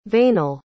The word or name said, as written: veinal